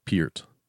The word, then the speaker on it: Peart: Peart. What was his last name again? – Peart. – P-E-A-R-T.